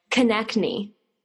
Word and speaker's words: Konecny: And this is the question you get all the time, but Konecny, that's your last name.